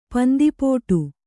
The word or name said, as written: ಪಂದಿಪುಟ್ಟು